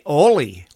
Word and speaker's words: Ole: Now, here in Minnesota, when you see the word or the name O-L-E spelled, you say Oli, like in Oli and Lena. That's how you say it here in Minnesota.